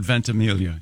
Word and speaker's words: Ventimiglia: Is it Ventimiglia? – Does he go with the soft G or does he do the Mike Birbiglia thing? – I have heard the soft G. – I've heard Ventimiglia, yes.